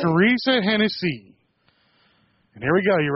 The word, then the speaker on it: Hennessy: Her name is Teresa Hennessy. – All right. – I think I'm saying that right. – That's the correct pronunciation of it.